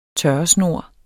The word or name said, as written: tørresnor